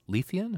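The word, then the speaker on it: Leithian: Tolkien, of the critique that Lewis made of Tolkien's poem, The Lay of Lethian. Am I saying that right? – Yes, I believe so.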